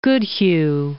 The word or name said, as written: Goodhue